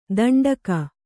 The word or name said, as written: ದಂಡಕ